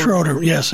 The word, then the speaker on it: Schroeder: Schroeder, Schroeder yes.